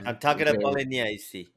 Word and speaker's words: Sant'Agata Bolognese: Sant'Agata Bolognese.